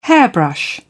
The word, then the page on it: hairbrush: British English